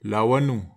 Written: lawanu

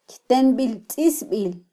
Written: kytembiltzisbil

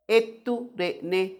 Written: ettu renne

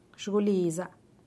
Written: xugulisa

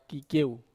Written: kakieu

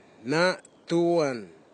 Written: natuan

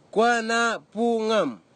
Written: kwanapugim

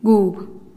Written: guub

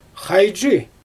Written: xajdʒɯj

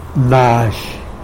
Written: nazhy

